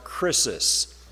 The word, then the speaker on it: krisis: I want to deal with a Greek word here, and the Greek word is pronounced krisis.